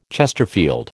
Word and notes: chesterfield: American English

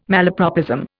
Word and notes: malapropism: Indian

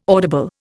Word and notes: audible: Indian

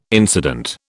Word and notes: incident: British English

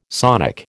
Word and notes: sonic: American English

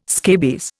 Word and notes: scabies: Indian

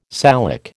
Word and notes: Salic: American English

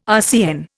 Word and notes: RCN: Indian

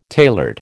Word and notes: tailored: American English

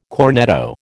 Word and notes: cornetto: American English

